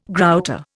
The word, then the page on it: grouter: Indian